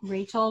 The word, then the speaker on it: Rachael: And you can find me on Facebook, Rachael with an A.